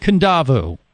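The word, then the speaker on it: Kadavu: We're going to spend a week there at Matava on the island of Kundavu. I've learned that much now, how to say the island that Matava is on.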